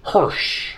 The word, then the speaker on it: Hirsch: Hirsch.